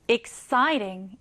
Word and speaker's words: exciting: 강세는요, 여기에 넣어주시면 돼요. exciting 이렇게요.